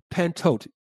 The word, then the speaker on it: pantote: Matter of fact, the word "always" in that verse in Matthew 26, 11, the word "always" is the Greek adverb pantote, which is spelled P-A-N-T-O-T-E, pantote, mm-hmm.